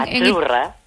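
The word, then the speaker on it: Azzurra: Achurra. – Achurra. Mira, ¿ves? – Achurra. – Es que... – Sí, como porque pizza. La Z no es la Z como decimos en catalán tan sonora, no. Es achurra.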